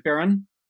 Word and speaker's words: Barron: About are, you, are, you, talking about Bishop Barron? It, it rhymes with uh, Went Born. Um, so, so, rhymes with Went Born.